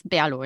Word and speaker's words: BRLO: Also man schreibt euch B-R-L-O, wie spricht man es aus? Berlo, ja? Das ist richtig, so wie ich es die ganze ja, Zeit sage.